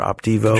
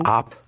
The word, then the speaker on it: Opdivo: This Opdivo, you've seen an ad on television for Opdivo. – Did you say Devo? – Opdivo.